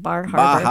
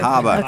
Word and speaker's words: Bar Harbor: You got to say it right. It's not Bar Harbor. – Bahaba.